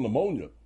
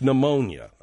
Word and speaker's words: pneumonia: Not called pneumonia, man. It's called pneumonia. – No, it isn't. – Give me that, bud. You don't pronounce the P. – Oh. – It's pneumonia, okay?